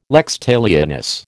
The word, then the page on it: lex talionis: American English